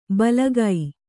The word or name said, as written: ಬಲಗೈ